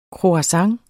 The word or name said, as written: croissant